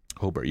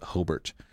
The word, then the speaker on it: Hobart: Hobart. – You got to pronounce it Hobart, not Hobart.